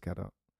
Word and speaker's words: Gadot: But in looking that up, the new Wonder Woman film with Gal Gadot, I don't actually know how to say her last name. I think it's Gadot.